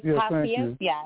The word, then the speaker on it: paciencia: Por su, the last word is paciencia. And in Spanish, it's spelled P-A-C-I-E-N-C-I-A.